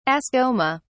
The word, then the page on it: ascoma: British English